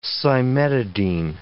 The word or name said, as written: cimetidine